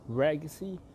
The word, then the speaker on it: Regacy: It's actually pronounced as Regacy. It's just the same as legacy, just that I'm using my front three letters, my first three letters for the name of this brand that I have.